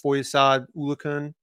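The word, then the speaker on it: Foyesade Oluokun: You know, Christian Kirk, defensively, they drafted Trayvon Walker, Devin Lloyd, and signed, how do you say this, Foyesad Ulukun. I think that's about close. – That's good.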